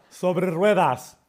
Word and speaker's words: sobre ruedas: Oh, sobre ruedas.